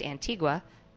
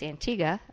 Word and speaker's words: Antigua: Virgin Islands, and then Antigua, which I always had pronounced Antigua, but it's Antigua, apparently.